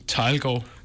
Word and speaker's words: Thielgaard: Thielgaard.